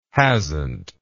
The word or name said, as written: hasnt